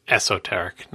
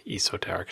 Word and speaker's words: esoteric: So on a more what Marco was trying to get on you about is that it's esoteric, not esoteric. It's not a long e.